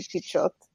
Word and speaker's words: Pichot: Like all of our last names are like of some European extraction, but I think most people pronounce it Pichot because okay. Pichot, we don't have the sh sound in Spanish, but anyway, Keith.